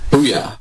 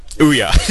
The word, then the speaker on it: Ouya: Ouya. – Ouya.